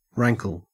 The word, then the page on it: rankle: Australian English